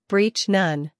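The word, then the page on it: breach: uk briːtʃ; en-us briːtʃ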